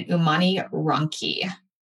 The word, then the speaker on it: Umani Ronchi: It's called an Umani Ronchi. It's spelled U-M-A-N-I and then R-O-N-C-H-I for all of those that don't speak Italian, including me.